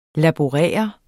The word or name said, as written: laborere